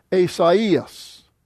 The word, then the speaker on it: Esaias: which means that the vowels are not supposed to be spoken together, they're supposed to be split apart. Instead of I, it's I. So that's why it's pronounced Esaias.